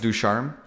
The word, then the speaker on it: Ducharme: Is I'll it Dominique guys... Ducharme? – Yes. That, I didn't know how to say his name, but that was it right there. Dominique Ducharme.